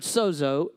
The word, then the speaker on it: sozo: It's actually spelled just S-O-Z-O, but it's pronounced sozo, okay?